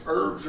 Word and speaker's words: herbs: How's it pronounced? Herbs or herbs? – Herbs. – Herbs. – With an E. Even though it has an H up front.